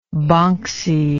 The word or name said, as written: bonxie